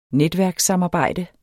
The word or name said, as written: netværkssamarbejde